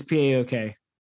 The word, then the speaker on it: PAOK: Yeah, I said C instead of K. It's PAOK.